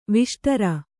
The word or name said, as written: ವಿಷ್ಟರ